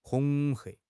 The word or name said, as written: 恐吓